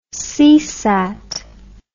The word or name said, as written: Seasat